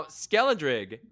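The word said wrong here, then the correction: Skeledirge: is awesome. – Isn't it Skeledurge? – Skeledurge, thank you.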